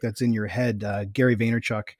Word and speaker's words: Vaynerchuk: It's Vaynerchuk is that's how he pronounces it sometimes.